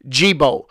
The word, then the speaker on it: Gibaut: His name is G-I-B-A-U-T, and I think it's pronounced Gibault.